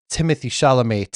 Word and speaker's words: Timothée Chalamet: Yeah, apparently you pronounce Timothée Chalamet, Timothée Chalamet.